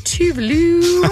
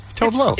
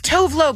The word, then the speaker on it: Tove Lo: How do you say it? Do you know? – Tove Lo. – It's Tove Lo, but then she said it was like Tuvalu or something.